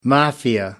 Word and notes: mafia: American English

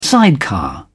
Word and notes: sidecar: British English

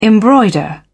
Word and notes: embroider: British English

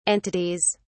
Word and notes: entities: British English

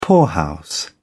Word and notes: poorhouse: British English

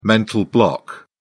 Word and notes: mental block: British English

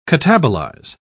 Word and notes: catabolize: British English

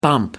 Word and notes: bump: American English

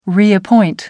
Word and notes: reappoint: American English

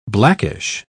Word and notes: blackish: British English

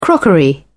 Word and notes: crockery: British English

